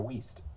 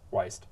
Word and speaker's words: Wiest: Another one that we're going to go over is 101 Essays That Will Change the Way You Think, from Brianna Wiest. And, oh, I just said that wrong. Wiest.